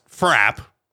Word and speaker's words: frappe: Because in the Boston area, a frap, pronounced frap, it's not with the frappe, it's frap. It's a thick milkshake with ice cream derived from the French word frappe. In France, it's frappe. In Boston, frap.